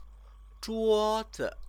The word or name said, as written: zhuozi